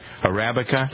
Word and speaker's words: Arabica: USDA certified organic coffee, shade grown coffee, top Arabica grade coffee. Why is it so hard for me to pronounce that? Arabica? – Arabica.